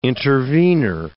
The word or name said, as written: intervenor